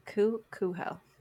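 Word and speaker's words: Kuchel: Yeah, I had to write like a pronunciation thing for you. It's Kuho. – Kuho. Okay. – Cool. See, still can't do it even though I have it written down. Cool. – It's K-U-C-H-E-L, people.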